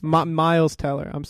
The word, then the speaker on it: Miles Teller: Oh, Myers Teller. That's Miles Teller. I just said it wrong.